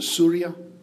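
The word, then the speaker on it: Surya (سوريا): Um, if you hear somebody from Syria pronouncing the name, they, they pronounce, uh, David, am I right? They pronounce it Surya? Yeah, Surya.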